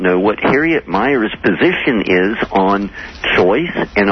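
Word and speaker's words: Miers: and he mispronounced her name. He called her Harriet Mears. I hope, by the way, that I'm pronouncing it correctly and that it's Anderson and not myself who's inaccurate here.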